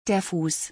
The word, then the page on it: der Fuß: German